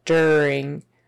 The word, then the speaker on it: during: We say that you are as an ER, kind of during, not during, but during. Uh, you might hear some people saying during, but typically in the Western United States, it's during, during, and that ing on the end, we don't pronounce the G.